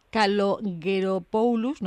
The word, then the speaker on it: Kalogeropoulos: Recordamos que María Ana Sofía Cecilia Kalogeropoulos, no sé si lo he dicho bien, pero... – Muy bien.